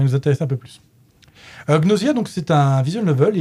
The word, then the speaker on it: Gnosia: Bref, on va parler de Gnosia. Alors, petit tour du proprio. Gnosia ou Gnosia, pour chez les anglophones, c'est comme ça qu'ils prononcent chez eux.